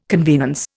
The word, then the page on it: convenance: Indian